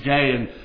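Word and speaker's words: gay (γῆ): The Greek word, if you read in verse 45 for land, is a Greek word which is pronounced gay, and it's translated land.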